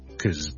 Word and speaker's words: because: Because he's a genius okay, 就是這麼弱的了。那這個呢就是絕大部分情況我們讀 because 的時候的讀法，當我們讀得慢一點了，咬字咬得正啲嘅時候咧，就可能會讀成 because，否則咧好 hea 地 because 就可以啦，或者就咁 cause 就可以啦。